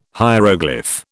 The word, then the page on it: hieroglyph: British English